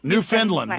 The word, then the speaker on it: Newfoundland: A lot of heat if you call it Newfoundland. – You'll get Newfoundland, or how do you say it? – Newfoundland, like Newfoundland. The Newfoundland. – Yeah. – Got it. – That's better.